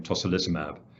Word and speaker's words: tocilizumab: Well, David, the drug is Actemra, and you did a wonderful job pronouncing the generic name tocilizumab, and yes, we've been studying this right from the very early days of the pandemic.